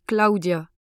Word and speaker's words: Klaudia: Bonjour, je m'appelle Claudia. Ça s'écrit K-L-A-U-D-I-A.